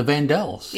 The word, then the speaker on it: Vandellas: They danced to a medley of Stevie Wonder's Uptight, Martha and the Vandellas, Dancing in the Streets, V-A-N-D-E-L-L-A-S, Vandellas. Oh, I always thought it was Martha and the Vandellas. – It is the Vandellas. That's how you say it.